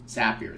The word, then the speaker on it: Zapier: Zapier is actually how you say it, and allows you to send this data into 1,000 different apps.